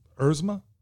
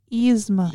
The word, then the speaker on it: Yzma: Erzma. Yzma.